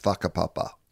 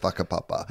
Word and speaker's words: whakapapa: I believe it is pronounced whakapapa, but it's spelled W-H-A-K-A.